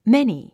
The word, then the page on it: many: méni; メニィ